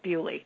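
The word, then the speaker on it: Beaulieu: And that's actually the way it's pronounced. I talked to a British guy who was like, no, you're pronouncing it wrong. It's Bewley.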